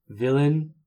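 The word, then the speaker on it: villein: Villain. Okay, you can pronounce it villain.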